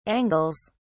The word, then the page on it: angles: British English